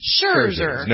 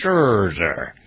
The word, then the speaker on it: Scherzer: How do you pronounce his name? Scherzer? – Scherzer. – No T. Scherzer. Okay.